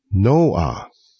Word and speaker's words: Noah: 您知道挪亚这个名字在英语系统里怎么念吗？ Noah，两个音节。在阿拉伯语只剩下一个音节，叫做 Nu。